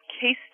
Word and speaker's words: CACE: He works at a company called Case Technologies, and that's spelled C-A-C-E, Case Technologies.